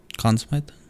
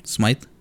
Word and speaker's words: Conn Smythe: How do you say the trophy again? – Conn Smythe. – Conn Smythe? – Smythe. – Smythe? – Mm-hmm.